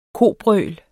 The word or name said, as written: kobrøl